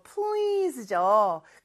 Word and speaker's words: please: 자, S인데 발음은 Z죠, 그쵸? 자, 그래서 please 이렇게 발음해 주셔야 되고요.